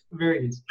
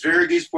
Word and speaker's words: Varghese: So, Varghese. It's like Portuguese. Varghese Portuguese.